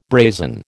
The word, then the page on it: brazen: American English